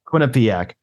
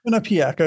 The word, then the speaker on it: Quinnipiac: It will begin its national title defense Saturday, playing either Penn State or Quinnipiac. Is that how you pronounce that? – Quinnipiac. – Quinnipiac. Okay, I just had to double check on that one.